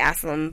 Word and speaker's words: Asseylum: And so the High Princess of Varus, Asylum, well, I say Asylum, but her name is spelled Asseylum.